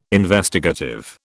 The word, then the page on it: Investigative: British English